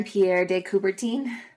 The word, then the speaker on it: Pierre de Coubertin: Baron Pierre de Coubertin.